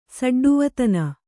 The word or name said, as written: ಸಡ್ಡುವತನ